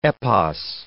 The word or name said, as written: epos